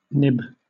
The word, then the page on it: nib: British English